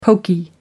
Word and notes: poky: American English

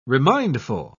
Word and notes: remindful: British English

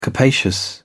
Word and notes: capacious: British English

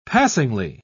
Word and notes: passingly: British English

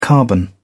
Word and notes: carbon: British English

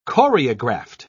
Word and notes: choreographed: British English

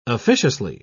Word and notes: officiously: British English

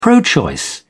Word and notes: pro-choice: English; British English

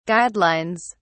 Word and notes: guidelines: British English

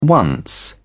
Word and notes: once: British English

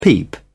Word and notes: peep: British English

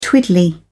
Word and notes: twiddly: British English

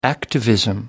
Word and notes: activism: British English

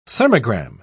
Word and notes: thermogram: British English

